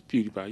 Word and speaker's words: PewDiePie: It's PewDiePie, you said it right.